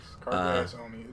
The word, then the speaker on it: Carcassonne: How do you say this game? Carcassonne? – Carcassonne. – Carcassonne. – Yes, Carcassonne. It's a strat game.